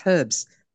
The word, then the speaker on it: herbs: You don't, to you, the H is silent, isn't it? – Yeah. – But there's an H there. It's not silent, it's herbs.